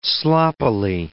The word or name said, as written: sloppily